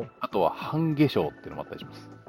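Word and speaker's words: 半夏生: 半化粧は、半に夏が生きると書いて半化粧って読むんですけど、はい、ということでですね、いろんなこう太陽暦、つまり、太陽の周りを地球が回っていくので何度になったらどの季節っていうタイプの太陽暦として決まっているのが二十四節気ですと。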